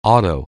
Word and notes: auto: anglais

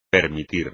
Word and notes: permitir: espagnol

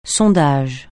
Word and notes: sondage: francais

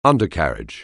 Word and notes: undercarriage: anglais

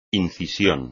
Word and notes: incisión: espagnol

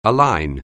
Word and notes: align: English

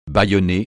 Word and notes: bâillonner: francais

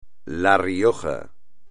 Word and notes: La Rioja: espagnol

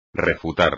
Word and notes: refutar: espagnol

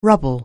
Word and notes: rubble: anglais

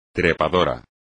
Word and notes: trepador: espagnol

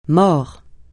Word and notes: mors: francais

